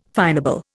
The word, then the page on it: finable: Indian